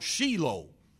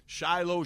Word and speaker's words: Shiloh: Others point to the root meaning of Shiloh, which in Israel they pronounce Shiloh. Shiloh, Shiloh, Shiloh.